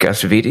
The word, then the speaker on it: Acevedo: She plays well up against Kirk Acevedo.